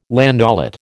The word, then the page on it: landaulet: American English